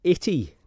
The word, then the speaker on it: itty: Well, it's usually the word itty, a word which rhymes with gritty, giving a clue to its earthy roots.